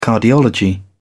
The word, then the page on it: cardiology: British English